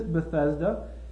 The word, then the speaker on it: Bethesda: And in my study, I have come to think that probably the best way to pronounce it is Bethesda. And frankly, that's quite convenient because we have a Bethesda, Maryland, which makes it easy for us to remember the pronunciation.